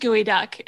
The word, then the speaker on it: geoduck: And it's called, she said gooey duck, but then it was geoduck. So what are we at now? – It's spelled geoduck, but it's pronounced gooey duck.